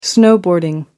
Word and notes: snowboarding: American English

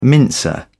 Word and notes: mincer: British English

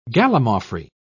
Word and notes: gallimaufry: British English

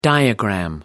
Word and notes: diagram: American English

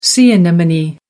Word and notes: sea anemone: American English

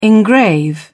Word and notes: engrave: British English

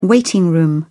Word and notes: waiting room: British English

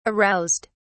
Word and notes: aroused: British English

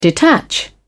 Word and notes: detach: British English

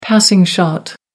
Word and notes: passing shot: American English

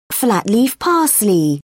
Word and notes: flat-leaf parsley: British English

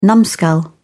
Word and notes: numskull: British English

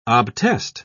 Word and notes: obtest: British English

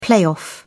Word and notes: playoff: British English

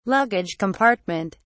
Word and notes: luggage compartment: British English

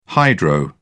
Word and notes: hydro: British English

